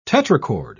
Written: tetrachord

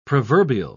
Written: proverbial

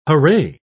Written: hurray

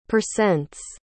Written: percents

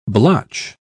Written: blotch

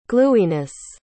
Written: glueyness

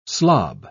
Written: slob

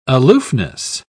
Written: aloofness

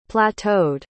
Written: plateaued

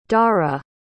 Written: dhaara

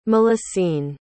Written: mélusine

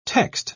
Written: text

